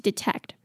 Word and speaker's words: detect: 然后发出特的音而已就结束了，他就接下去念下一个字了。所以 trying to detect, OK, 这可能要练习多遍一点才会把它念顺 OK, trying to detect. 那 detect 呢，它的、两个 e 是不同的发音哦。第一个 e 呢是 e 的音，那第二个 e 是 at 的音。